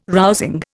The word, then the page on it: rousing: Indian